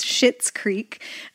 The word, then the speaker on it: Schitt's Creek: And it's spelled S-C-H-I-T-T, so it's Schitt's Creek, that you had said was your favorite comedy before you discovered The Good Place.